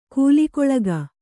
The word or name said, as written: ಕೂಲಿಕೊಳಗ